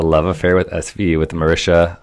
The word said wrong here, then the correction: Mariska: Har- Mariska.